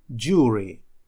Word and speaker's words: jury: Jury, jury. То есть здесь буква U наша читается звуком U.